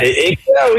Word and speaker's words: éclair: Éclair. What's that? – Éclair, oui, c'est ça.